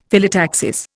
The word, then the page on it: Phyllotaxis: Indian